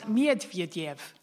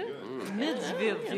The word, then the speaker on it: Medvedev: It's Medvedev. That's how you pronounce it. – Is that right? – Medvedev. Yeah, thank you very much.